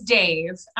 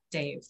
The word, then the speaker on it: Dev: But it is pronounced, Dave. So like E makes like a almost like an A-T-H sound.